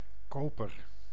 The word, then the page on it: buyer: /ˈbaɪ.ə(ɹ)/